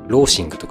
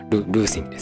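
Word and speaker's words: loathing: ローシングとか言いたくなっちゃうんですけど、ルーシングです。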